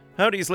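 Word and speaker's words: Hovvdy: Although the W is spelt with a double V, so Havd, but Howdy is how you pronounce it.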